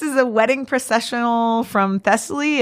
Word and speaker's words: Thessaly: So, you know, I tried to find something and I found some odd lute music, and I have a wedding processional from Thessaly. And I don't know if I'm saying, You're saying it right.